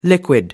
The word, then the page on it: liquid: American English